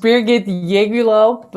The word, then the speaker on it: Birgit Yegülalp: Yeah, it just depends where you come from. I pronounce it as Birgit Yegülalp, but I have to admit, it's a very strange name.